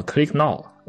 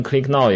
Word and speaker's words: Click Now: 对，我现在做了一个苹果上的就是 macOS 的 app，叫 Click Now， CLICK，然后 NOW，你可以读成 click 点击嘛，点击。 – 对，你可以读成 Click Now，也可以读成就是把后面四个字母连起来，就是后面四个字母是 know，就是知道。对，所以就是 Click Now，